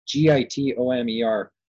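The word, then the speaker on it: Gitomer: Jeffrey Gittimer. Boy, I hope I'm saying it right. It's Gittomer. So his book is called The Little Red Book of Selling.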